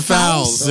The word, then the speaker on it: Fowles: The nominees are Sylvia Foyles, Fowles? – Sylvia Fowles. Yes. Fowles. I'll help you with the pronunciation.